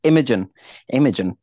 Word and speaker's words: Imogen: Imogen. I'm just gonna say that's why I didn't catch that you were saying her name before because I was like, are you saying image in Imogen? – Imogen.